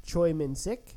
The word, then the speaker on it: Choi Min-sik: The actor's name is Choi Min-sik.